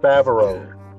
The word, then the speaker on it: Favreau: And then John, how you pronounce that? – Favreau.